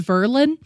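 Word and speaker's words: Verlyn: You know, I found out that we've been pronouncing her name wrong this whole time. It's Verilyn. Verilyn.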